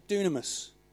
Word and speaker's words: dunamis: It's called dunamis. Now it's written dynamis. That's just a transliteration. It's pronounced dunamis.